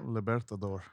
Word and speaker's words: Libertador: Libertador.